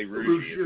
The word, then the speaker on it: Rougeux: It's French. So my dad will say Rougeux, but it's really Rougeux is how we said it.